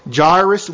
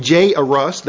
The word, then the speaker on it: Jairus: Jairus was his name. Some people pronounce his name Jairus. That's fine as well from the Greek.